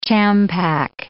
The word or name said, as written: champak